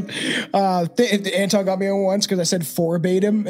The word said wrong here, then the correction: verbatim: and it's like it's verbatim.